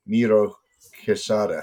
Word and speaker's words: Miro-Quesada: Here with Oscar Miro-Quesada. How do you like that? – Very well pronounced.